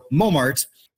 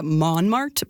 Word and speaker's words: Montmartre: So we ended up with March. So Montmartre. – Now, apparently in France, some English speakers will also drop that tricky last syllable, but they'll go with more of a Montmartre pronunciation.